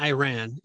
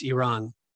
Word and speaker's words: Iran: It's not Iran. It's Iran. So just, you know, that's the right way to say it.